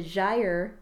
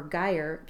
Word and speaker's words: Gyre: The other thing I finished is the Gyre or Gyre cowl. It's spelled G-Y-R-E.